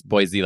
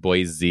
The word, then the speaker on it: Boise: We have Salt Lake City, Boise, which I've been told, Mom, you don't pronounce Boise like Boise. You're supposed to pronounce it Boise with an S, not a Z.